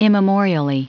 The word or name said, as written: immemorially